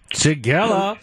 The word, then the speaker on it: Shigella: Shigella. – You got that right. – Aha! – Shigella.